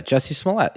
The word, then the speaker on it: Jussie Smollett: That guy, forgive the poor pronunciation. Juicy Smollett. – Oh yeah, Jussie Smollett.